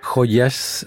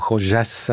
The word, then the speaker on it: Joyas: Joyas, Joyas ou Joyas en prononciation argentine, ah, oui.